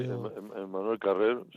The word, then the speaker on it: Emmanuel Carrère: y es Emmanuel Carrère. No sé si lo pronuncio bien. Emmanuel Carrère. – Sí, Emmanuel Carrère, sí, perfectamente.